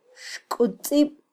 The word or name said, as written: xkutzib